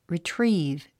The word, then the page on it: retrieve: ritríːv; リィトリーブ